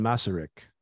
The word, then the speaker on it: Masaryk: Jan Masaryk. Tell me if I said that right. – You did. Well, look, bear in mind, I get told different pronunciations, but that's how I would say it.